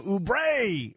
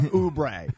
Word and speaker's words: Oubre: He calls him Oubre instead of Oubre.